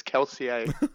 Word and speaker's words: Kelsier: Marsh, they can't take Kelsier, whatever his name is. – Kelsier, I will infect you. – No. – Brandon Sanderson is wrong. The correct way to say it is Kelsier.